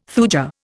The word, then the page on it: thuja: Indian